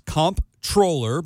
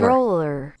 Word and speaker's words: comptroller: Controller. – Oh, no way. – Say it again. – Controller. – Controller. – Controller. – Which is an N, not an M or a P.